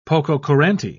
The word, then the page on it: pococurante: British English